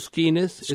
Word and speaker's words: Skenes: You know, right-handed transfer, if I'm pronouncing it right, Paul Skenes. Is it Skenes or Skenes? – Skenes.